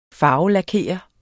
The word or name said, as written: farvelakere